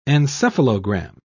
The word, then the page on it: encephalogram: British English